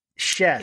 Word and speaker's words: Schiess: She mentioned that, pronounced like chess. Schiess.